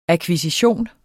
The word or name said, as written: akkvisition